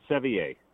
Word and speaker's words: Sevier: Sure, it's French. Sevier, Dark Sevier.